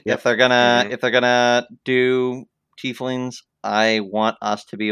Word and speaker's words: Tiefling: Tieflings. – It's not Tiefling? – It's not Tiefling. It's Tiefling.